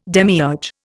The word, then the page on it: demiurge: Indian